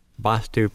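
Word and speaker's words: Vaastu: Vastu pronounced with an A is pronounced quickly and spelled V with one A, S-T-U. And Vastu, pronounced with the longer time held for the A and spelled V-double-A-S-T-U.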